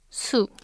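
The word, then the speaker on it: su (수): This Chinese character is pronounced as su.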